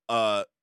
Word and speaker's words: Jonghyun: But in 2017, Jonghyun, I hope I'm saying that right.